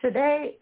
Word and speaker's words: Sade: Kool and the Gang, Oasis, Sinead O'Connor, Sade, Sade, how do you say her name? – Sade? Yeah. Mm-hmm.